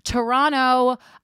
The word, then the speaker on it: Toronto: Toronto, I'm making my grand return to Canada February 17th and 18th.